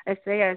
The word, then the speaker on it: Estrella: Okay, so I was pronouncing it wrong. It's not Estrella, it's Ya at the end. – Yeah, it's a Spanish. Yeah, it's a Spanish name.